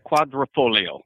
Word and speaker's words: Quadrifoglio: Pronounce it for me. I'm terrible with these pronunciations. – Well, there's the Giulia, and then there's the Giulia Quadrifoglio.